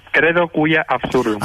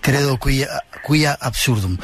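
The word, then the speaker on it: Credo quia absurdum: Credo qui absurdum. – Ah, cuia, vaya, perdón, es que mi latín, está un poco oxidado. Credo cui absurdum.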